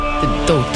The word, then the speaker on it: Gadot: Today, I'm going to teach you how to pronounce the name Gal Gadot. – Gadot. – Gadot. Gal Gadot.